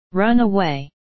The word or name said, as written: run away